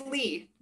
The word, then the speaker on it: Leigh: And it's Lee.